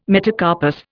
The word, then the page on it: metacarpus: Indian